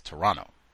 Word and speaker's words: Toronto: Toronto, B said the second T is silent, so it's Toronto.